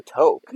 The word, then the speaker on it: toque: And I'm like, a toque. Is that, it's pronounced toque? – Yeah. – I thought it was always pronounced a toque. – No, it's a toque.